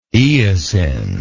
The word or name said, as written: eosinlike